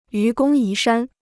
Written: 愚公移山

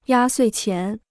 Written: 压岁钱

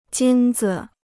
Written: 金子

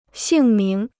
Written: 姓名

